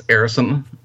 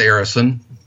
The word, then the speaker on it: Areson: Arison on Facebook. – Okay. – You can try to Skype me, C.S. Arison. A-R-E-S-O-N, that's the last name.